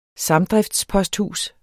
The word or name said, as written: samdriftsposthus